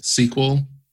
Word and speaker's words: Sequel: SQL? – Yeah, it's spelled S-E-Q-U-E-L.